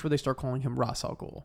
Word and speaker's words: Ra's al Ghul: show they call him Ra's al Ghul.